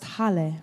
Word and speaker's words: Halle: pronounced Halle, right? Okay, good. Thank you for having me, Halle.